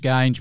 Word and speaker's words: Geange: How do you say it? Strange Geange, mate, is how Geange.